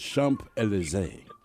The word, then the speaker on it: Champs-Élysées: It is Champs-Élysées.